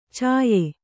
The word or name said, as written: ಛಾಯ